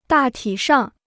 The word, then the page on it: 大体上: dà tǐ shàng